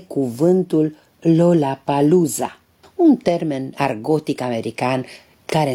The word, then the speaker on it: Lollapalooza: În limba japoneză însă nu există sunetul L, Japonezul care se declara filipinez ca să poată spiona la americani pronunța greșit Roraparuza.